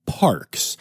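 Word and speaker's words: PaRx: So the Toronto Zoo is the first zoo to participate in a program with Parks, which is sounds like Parks, but it is spelled P-A, then capital R-X, like a prescription, which is a natural prescription plan that is being piloted right now in Canada.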